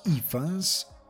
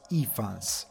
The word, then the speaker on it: Ifans: Martin, cast Rhys Ifans, quanto pare si legge Rhys Ifans, perché è gallese, quindi non dite iFans, è iFans.